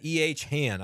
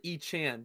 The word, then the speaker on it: ECHANN: E is that EH Han, I guess, would be the way that you would pronounce that. Middle name? – So it's actually E Chan, because E-chan. My middle name starts with a C.